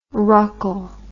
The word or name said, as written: ruckle